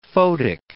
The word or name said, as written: photic